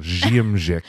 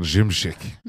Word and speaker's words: Szymczyk: The S Z Y is like a J sound. Szymczyk. – Szymczyk. Okay, well, producer Bill, last name unpronounceable. – I'm saying Szymczyk.